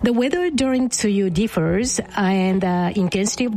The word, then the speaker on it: tsuyu: Very sorry if I butcher it, but you said it's tsuyu? – There you go. Yes.